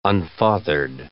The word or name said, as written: unfathered